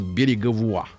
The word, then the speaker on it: Береговуа: В 25-м году Пьер Береговуа, ну то есть Береговой, наш человек, а по-французски Береговой читается как Береговуа.